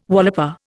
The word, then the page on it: walloper: Indian